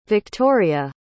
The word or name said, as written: Viktoriya